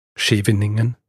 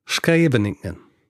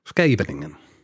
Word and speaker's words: Scheveningen: Ich habe über einen niederländischen Ort gesprochen und ich habe ihn halt sehr deutsch ausgesprochen. Äh, heißt nicht Scheveningen, wie ich gesagt habe, sondern Scheveningen. Scheveningen.